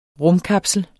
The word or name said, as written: RUMKAPSLER